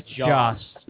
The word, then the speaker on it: Joss: So for those of you, Josh Whedon just posted pictures. – I don't know why you always pronounce it Josh. – I know. – Joss.